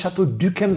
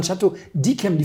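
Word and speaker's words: Château d'Yquem: Man sagt ja auch nicht Chateau Duquem, sondern Chateau Diquem. Die Franzosen sagen, Y wird I ausgesprochen. Ihr könnt doch nicht Syrah sagen.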